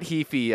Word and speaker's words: Heafy: But that's, Matthew Kifihichi's. I said that wrong. Matt Heafy of The Way of Trivium.